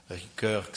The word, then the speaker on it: Ricoeur: Eu tenho que lembrar, falando nisso, até eu estou parafraseando um pouco uma frase dele, o Paul Ricoeur, que é um grande filósofo francês, Ricoeur, que se escreve R-I-C-O-E-U-R,